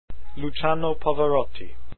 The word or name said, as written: pavarotti